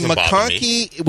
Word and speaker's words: McConkie: I mean, McConkie, which rhymes with honky, and Pearsall.